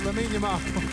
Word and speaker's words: Lamine Yamal: If I said Lamine Yamal, would that sound right to you? – Yeah, that sounds right. – There we go, Lamine Yamal. That's correct.